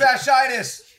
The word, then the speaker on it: fasciitis: Fasciitis.